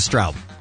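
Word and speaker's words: Stroup: Yes, last week we had Sinead Grimes and we had Jessica Straub from 90210. And probably Jessica Stroop, too. She was probably on. – She said her name is Straub.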